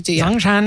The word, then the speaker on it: Zhongshan: You didn't say it right. – Zhongshan. – That's good. – Zhongshan.